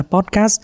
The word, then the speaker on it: podcast: hãy chú ý đọc đúng là podcast đặt âm sờ vào chữ cast chứ đừng đặt vào chữ pod nhé từ podcast ý,